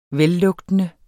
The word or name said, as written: vellugtende